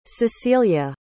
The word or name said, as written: cecelia